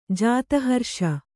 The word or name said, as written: ಜಾತಹರ್ಷ